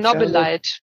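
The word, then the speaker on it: Chernobylite: Das ist, ja, was sagen wir denn zu Chernobyl Light oder wie wird das ausgesprochen? – Chernobyl Light.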